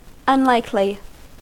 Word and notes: unlikely: American English

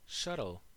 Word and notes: shuttle: American English

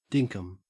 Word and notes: dinkum: Australian English

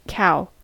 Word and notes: cow: American English

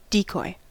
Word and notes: decoy: American English

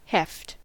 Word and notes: heft: American English

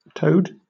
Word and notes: toad: British English

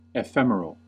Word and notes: ephemeral: American English

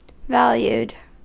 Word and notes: valued: American English

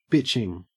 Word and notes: bitching: Australian English

